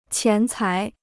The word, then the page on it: 钱财: qián cái